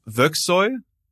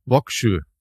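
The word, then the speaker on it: Vågsøy: Etwa in Operation Archery, was ein Angriff auf eine deutsche Garnison im norwegischen, ich weiß jetzt nicht, wie man es ausspricht, Vöxoy. – Wie schreibt man's? – Mit einem Kringel A oben. V Kringel A G S O Y. – Vöxö. – Vöxö. Vöxö. – Ein Ö ist eine Insel. Also heißt Insel.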